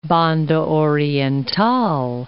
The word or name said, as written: Banda Oriental